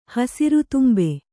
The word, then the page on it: ಹಸುರುತುಂಬೆ: Kannada